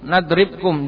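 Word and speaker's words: nadribkum (نضربكم): Nadribkum dibaca sukun.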